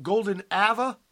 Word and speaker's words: Golden Ava: Look, am I pronouncing this right? Golden Ava? Like Ava Gardner? – Golden Ava. – Ava. Okay. Let's listen.